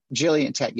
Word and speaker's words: Gillian Tett: Okay, so that was Gillian. I'm not sure how to say it. – Yeah, Gillian Tett. Yeah, that's right.